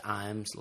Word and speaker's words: Imes: And then we have Brandon Imes, whose last name is spelled like I, James, but it's pronounced Imes, like the cat food.